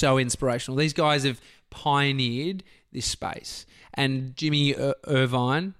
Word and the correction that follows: Jimmy Iovine: Jimmy Iovine, fucking don't know how to say his name.